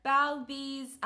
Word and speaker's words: Bowlby: that's how you pronounce his last name, Bowlby's theory of attachment.